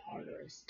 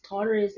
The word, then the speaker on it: Tartarus: T-A-R-T-A-R-S. Is that Tartarus? And is the only time it shows up in the Bible. Tartarus. Tartarus, a place of punishment in Greek stories.